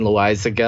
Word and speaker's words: Loaisiga: And I've got a lot of info on a lot of the guys I took, like Nick Senzel and Jonathan Loaisiga. I think that's how you pronounce his name for the Yankees. – That is correct.